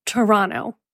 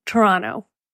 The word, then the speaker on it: Toronto: And that is how you say it, Toronto, like T-R-A-A-A-A-N-T-O, Toronto.